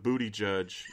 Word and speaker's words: Buttigieg: I read an article that said it rhymes with booty judge, like B-O-O-T-Y dash J-U-D-G-E.